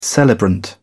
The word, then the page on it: celebrant: British English